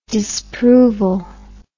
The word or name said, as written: disproval